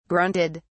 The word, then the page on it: grunted: British English